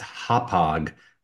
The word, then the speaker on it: Hauppauge: And I will say the most impressive thing that you just did was you actually pronounced Hauppauge correctly. That I have to ask, did my team coach you on that? Or did you actually know what Hauppauge is? Because that was impressive.